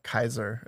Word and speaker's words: Caesar: Caesar was more like Kaiser is how they'd. Oh wait, Kaiser yeah. is how they'd pronounce it.